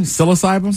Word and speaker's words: psilocybin: Psilocybin? – Psilocybin.